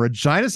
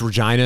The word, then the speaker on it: Regina: You know, it took me a long time to realize it's Regina and not Regina. but it's spelled Regina. Like you see it, and it's like anyone that has that name, they pronounce it Regina, but not there.